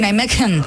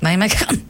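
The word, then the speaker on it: Nijmegen: Όχι, Ναϊμέκεν. – Λοιπόν, θα τρώσω ένα κριτσίνι, ένα κουλούρι, κάτι που να κάνει ψυχουλάκια, να έχει κανένα σουσάμι. – Καλαμπόκι. – Θα στραβοκαταπίνεις εκείνη την ώρα, οπότε θα σου βγαίνει φυσικό. – Ναϊμέκεν. – Ναι. – Λοιπόν, είναι από την Ολλανδία, γράφεται με G, αλλά η προφορά είναι κάτι ανάμεσα.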